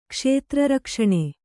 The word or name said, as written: ಕ್ಷೇತ್ರರಕ್ಷಣೆ